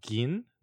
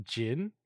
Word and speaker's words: Gin: I learned that the guy's name is pronounced Gin, not Jin, which I was like, oops, my bad.